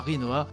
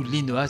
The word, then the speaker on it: Rinoa: Square Enix toujours, qui a annoncé un nouveau personnage pour Dissidia Final Fantasy, ce sera l'héroïne principale de Final Fantasy VIII, à savoir Rinoa, ou Linoa si vous le prononcez à la japonaise.